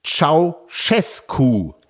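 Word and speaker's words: Ceaușescu: Meine Lieben, der erschossene rumänische Diktator wird ausgesprochen wie Ceausescu. Der ausgeschlossene Präsidentschaftskandidat wird ausgesprochen Georgescu. Das J jeweils weich, wie Georg im Englischen.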